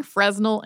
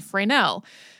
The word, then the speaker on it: Fresnel: The Lime Rock Lighthouse was an oil-burning lantern with a Fresnel lens, which can be pronounced in about 10 different ways, including Fresnel and Fresnel.